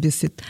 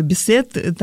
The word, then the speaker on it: Бисетт: В пресс-центре этого кинофестиваля я вдруг вижу, что идет навстречу мне Жаклин Бисетт. Ну, иногда говорят Бисетт, там разное ударение ее фамилии.